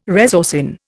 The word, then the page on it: resorcin: Indian